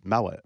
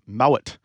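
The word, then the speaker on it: Moët: "People call it Moe. It's Moet is the correct way to say it."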